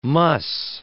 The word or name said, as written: muss